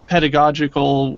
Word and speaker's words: pedagogical: You know, about a month ago, we sat down with a professor who said, hey, I have this this pedagogical, did I say that right, Brian? – Yeah, you nailed it.